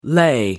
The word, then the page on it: lay: American English